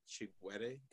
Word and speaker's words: Chigwere: Uh, like I said, it's just my name, uh, Shingirai Chigwere, and I'll spell that because it's a little complicated. Uh, S H I N G I R A I C H I G W E R E, and then also you can reach me on my um on my Instagram at the Shingy.